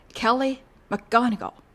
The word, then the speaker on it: ケリー・マクゴニガル: 英語でお名前発音しますと、ケリー・マクゴニガルという風になります。